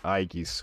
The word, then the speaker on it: Aegis: Αυτό Aegis γράφεται, Aegis προφέρεται. Ε, σου έχω ξαναπεί σε ένα λευκό γι' αυτό, περιμένω να το παίξεις ακόμα.